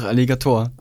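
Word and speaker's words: Alligatoah: und das Lied heißt sogar Trauerfeierlied von Aligota. Kennst du auch? – Ja. – Habe ich den jetzt falsch ausgesprochen? – Ja, ich glaube einfach Alligator. – Wie das Tier.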